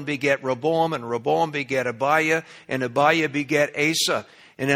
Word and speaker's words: Abijah: And in 2 Chronicles, that has a J in it. It's pronounced Abijah.